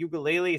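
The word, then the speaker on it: Uiagalelei: Washington State has a Heisman candidate at quarterback in Cam Ward, and Oregon State has DJ Uyunglele. Is that how you pronounce his last name? – Yep, nailed it.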